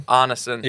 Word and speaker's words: Arneson: I keep saying Allison. – Arneson. Yep.